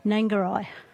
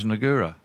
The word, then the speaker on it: Nangarai: How do you pronounce it? – Nangarai. – I thought it was Nagura. – No.